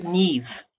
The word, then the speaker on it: Niamh: You can't look at the name, you know, like, you know, how would you get Niamh at N-I-A-M-H? But you have to know M-H is a V sound.